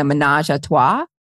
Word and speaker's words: ménage à trois: Oh, and it's called How to Plan a Ménage à Trois.